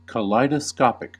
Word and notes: kaleidoscopic: American English

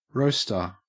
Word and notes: roaster: Australian English

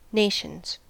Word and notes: nations: American English

